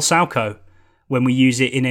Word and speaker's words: Salchow: It's capital S-A-L-C-H-O-W. It's named after an individual who invented the move. So whenever you hear a co-commentator moaning about a striker doing a triple Salco in the area, that's how it's spelt.